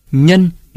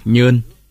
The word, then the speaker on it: Nhân: Nhân người Nam Bộ đọc là Nhơn.